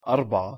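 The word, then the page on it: أربعة: Arabic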